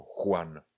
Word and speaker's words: Juan: Pochybuju, že někdo, kdyby napsal nebo vycítil nebo viděl slovo Juan, že by ho asi neřekl jako Juan, ale jako Juan. Ano, Juan. J je, je vyslovujeme jako ch.